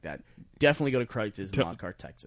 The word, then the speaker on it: Kreuz: Go to Kreitz's, though.